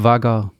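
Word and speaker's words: Vhagar: Ist vager.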